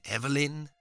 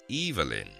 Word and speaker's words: Evelyn: Many pronounce it Evelyn. She always insisted that her name be pronounced Evelyn.